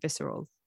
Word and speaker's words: viscera: towards a splay of viscera on the sand, riding thermals between infinity and death. I think you pronounce it visceral. – Yeah, viscera. Yeah.